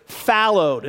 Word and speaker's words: fallowed: All right, the first one is fallowed. It's spelled like followed, but it's fallowed. F-A-L-L-O-W-E-D.